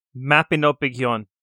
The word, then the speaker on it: Mabinogion: Mabinogion? – Hang on. Mabinogion.